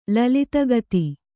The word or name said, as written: ಲಲಿತಗತಿ